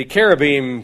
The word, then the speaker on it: cherubim: Maybe that was like this, except when the cherubim come, you know they're arriving. That's what we just described. These are cherubim, or cherubs, if you will. The CH is pronounced as a K, not a CH, and they aren't little fat babies with rosy cheeks and tiny wings.